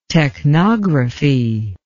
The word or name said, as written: technography